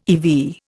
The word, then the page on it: eV: Indian